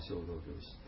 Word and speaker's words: 生老病死: でそれ結局これずっとどういうことかっていうと、まあ仏教的に言うと、まあ、生老病死っていうね、生まれて年取って病気になって死んで、まあこれちょっと仏教の読み方で生っていう生じゃないですね、生老病死っていう発音しますけども、